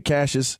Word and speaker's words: Cassius: You know, and Cassius looking at, is that how you say it, Cassius? – Yeah, Cassius Marsh. Yeah.